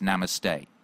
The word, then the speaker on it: namaste: It's not namast, it's namaste. Yo, or something like that.